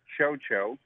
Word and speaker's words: Chocho: Well, it's pronounced Chocho. – Chocho. – C-H-O-C-H-O.